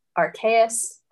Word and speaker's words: Arceus: But of course, when you think religion and Pokemon, for me at least, I think of Arceus or Arceus, as I believe it is canonically pronounced now. They've shifted that one and I wasn't ready for it.